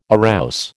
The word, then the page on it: arouse: American English